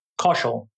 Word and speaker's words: Kaushal: Kaushal.